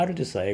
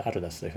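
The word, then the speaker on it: Aldus: まあ、アルデュスは英語でアルダスというふうに発音します。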